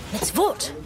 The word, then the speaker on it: mitzvot: Well, I know there are four mitzvahs. No, mitzvot. That's how you say it.